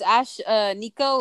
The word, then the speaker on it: Ashnikko: Is it how it's pronounced? Like Ashnikko? – Yeah, it's Ash uh, Nico with N I K K O.